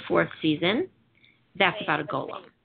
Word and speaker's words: golem: So it's a golem. It's pronounced golem. It's golem.